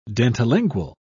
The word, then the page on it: dentilingual: British English